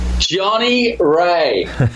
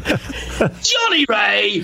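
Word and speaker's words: Johnny Rea: How do you say it? – Johnny Ray. – There you go. I interrupted it. Try it again. – Johnny Ray.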